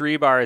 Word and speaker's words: Hribar: And Rich Rebar is spelled HRIBAR. – Yep, but Lord Reeves is REB. Yes, Lord Reeves. – Yep, but yeah, Rich Rebar HRIBAR is how he spells it.